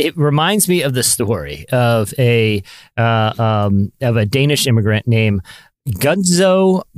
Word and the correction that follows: Gutzon: Are you sure you said that right? – I'm gonna spell it for you. Gutzon. That's his first name. – Gutzon. – Uh huh. And then Borglum. Borglum. – Yeah, Borglum. Yeah. – So anyway,